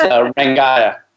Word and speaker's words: Rangaiah: my son has a great Twitter handle called That Gaia. So it's like it rhymes with that. So it's Rangaiah.